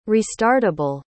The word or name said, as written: restartable